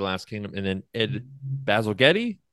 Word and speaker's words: Bazalgette: like jet engine, Bazalgette. – Oh, Bazalgette.